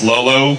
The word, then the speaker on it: L0L0: Just to be clear, it's pronounced Lolo, but it's actually L0L0,